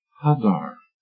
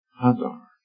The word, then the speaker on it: hadar: It is pronounced like this. Hadar. Hadar.